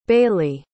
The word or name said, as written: bayley